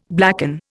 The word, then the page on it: blacken: Indian